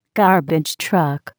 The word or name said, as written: garbage truck